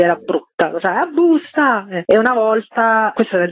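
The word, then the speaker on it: Boosta: Come mai questo busta, che non è con la U ma con la doppia O e pronuncia anglosassone?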